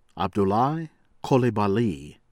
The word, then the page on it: Abdoulaye Coulibaly: American English; Ivory Coast